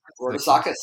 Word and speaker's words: Rodousakis: Today with me, I have a wonderful special guest, Christopher, how do you pronounce it, Rodousakis? – Rodousakis, yeah, pretty close.